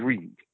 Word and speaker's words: Ried: My last name is R-I-E-D. Mm-hmm. I before E, except after C. So it's German, and in German you always pronounce the second vowel. So hence why Stein is Stein and Reed is Reed.